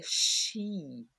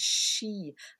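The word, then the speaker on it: sìth: And in the Gaelic culture, it was the little people, the still folk, the silently moving people called the Shi. It's spelled S-I-T-H, Sith, but it's pronounced She.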